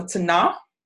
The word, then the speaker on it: Tanakh: So in your book on page seven, talks about the Tanakh. The K is silent um, before.